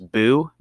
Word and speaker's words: Bo: Um, so guys, roast Lewis for saying it like that. It, it's definitely Bo.